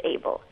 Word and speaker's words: ABL: And the gene involved in the Philadelphia chromosome, one of the genes, is ABEL, A-B-L, pronounced ABEL.